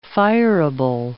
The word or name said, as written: fireable